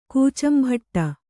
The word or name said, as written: ಕೂಚಂಭಟ್ಟ